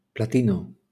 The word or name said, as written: platino